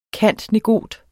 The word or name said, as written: cand.negot.